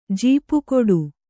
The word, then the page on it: ಜಪಗುಟ್ಟು: kannada; japa guṭṭu